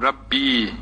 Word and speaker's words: Rabbi: Rabbi sonunda ya mütekellim yesi, yani benim Rabbim, Rabbi ye varmış, düşmüş Rabbi diye telaffuz ediliyor.